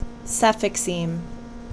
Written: Cefixime

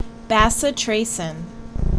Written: Bacitracin

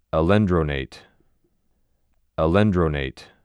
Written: Alendronate

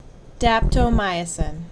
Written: Daptomycin

